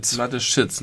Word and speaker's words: Mateschitz: wenn kommen Putin und die dann Mathe Sitsch. was? wie spricht man die aus? Mathe? Mathe Mateschitz, nicht Sitsch.